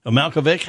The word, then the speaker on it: Malkovich: Malkovich, how do you say it? Vic or Vich? Vic.